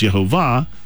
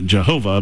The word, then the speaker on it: Yehovah: Now, the name Yehovah is pronounced Yehovah with the emphasis on the last syllable. Now, a lot of people say Jehovah, and the only real area there is it's the second syllable. It's not Jehovah, but Yehovah.